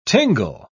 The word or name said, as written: tingle